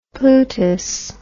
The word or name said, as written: plutus